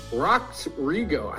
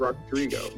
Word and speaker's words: Rodrigo: Ahoy, Rock Drigo. I always say Rock Drigo. – Rock Drigo? – Yep. – Rodrigo. Maybe that's why go. I always say it wrong.